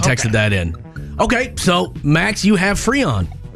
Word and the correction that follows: frisson: I really just pronounced Frisson. Frisson.